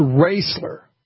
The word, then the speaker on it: Raisler: It's Raisler. Okay, got it. I think I went row rather than, you know, I didn't realize it was long A, but I thank you for someone that maybe knows somebody there.